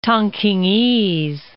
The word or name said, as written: Tongkingese